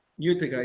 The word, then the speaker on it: Utica: Is that how you pronounce it? Utica? – Utica, yes.